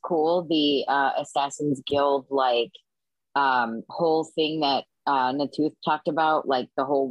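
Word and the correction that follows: Na'Toth: I know I said her name wrong again, sorry. – Natoth. Ivanova Natoth. – I said Ivanova right, but Natoth I did not say right.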